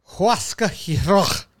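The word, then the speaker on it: Huascar Ynoa: Remember how good Huascar Ulloa was for them? I'm saying that right? – Huascar Ulloa.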